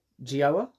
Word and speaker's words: Gioia: And he had Ted Gioia on. I am pronouncing that Gioia. Right, yeah? Gioia. Ah!